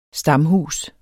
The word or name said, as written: stamhus